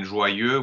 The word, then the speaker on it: joyeux: Exactly, and in joyeux, we have our first case scenario where the EU is the last pronounced sound in the word, and therefore it is also the O slash.